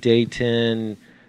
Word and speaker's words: Dayton: And everybody that comes here from Cincy, Dayton, Cleveland, Dayton. – There's a T in it. – Yes, but it's not Ton.